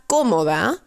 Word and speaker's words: cómoda: Ropa comoda met een accentje op de eerste O, want daar komt de klemtoon.